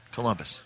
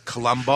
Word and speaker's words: Colombo: Columbus. – Wrong. Colombo.